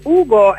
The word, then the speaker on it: UGO: Allora, sì, Ugo è così come si pronuncia, come Fantozzi, ma è un acronimo che non vi stiamo a dire, perché lo troverete su Facebook.